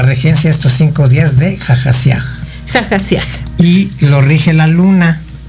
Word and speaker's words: Hahasiah: H-A-H-A-S-I-A-H. Pero como es hebreo, la H suena como J, entonces jajasiaj.